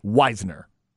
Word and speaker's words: Wisner: it is a capital W-H-Y-Z and then the little N-E-R, Wisner, for Trey Wisner.